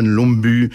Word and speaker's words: Lumbye: Hans Christian Lomby, ça s'écrit L-U-M-B-Y-E, enfin bon voilà, c'est pas toujours la même prononciation que chez nous, les voyelles, et c'est le rêve après le bal que voici, écrit en 1861.